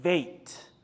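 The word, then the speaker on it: Vet: Whenever you have the dot in the B, it's Be. Aleph, Beit. But when the dagesh or the dot is out, the B is actually pronounced as a V. Aleph, Beit, Veit.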